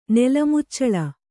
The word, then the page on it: ನೆಲಮುಚ್ಚಳು: Kannada